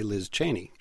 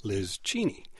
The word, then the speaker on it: Cheney: Okay, parsing this statement further, notice that she didn't say Liz Cheney. She said Liz Cheney. – Did she say that? – That's how they pronounce it back in Wyoming, and that's how her parents had pronounced it before they went east and heard everybody saying it a different way.